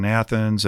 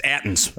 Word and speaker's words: Athens: So what we've done is, you know, a couple of gravel bikers like Shane Hintz out in Athens, and especially Athens has already really embraced this. – Minus 1 point for pronouncing Athens incorrectly. – It's Athens.